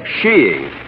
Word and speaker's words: skiing: shiing. – A little what? – Sheeing. Oh, there are other pronunciations, I suppose, but in England and Norway, sheeing is considered most acceptable.